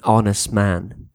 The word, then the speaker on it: honest: Or, for example, you can say an honest man. Be careful, the word honest, you don't have to pronounce the H, it's silent.